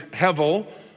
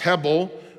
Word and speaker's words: hevel: As Pastor Steve taught us yes, uh, last week, rather, that Hebrew word is hevel, uh, or pronounced hebel, which actually means a mirage, an illusion.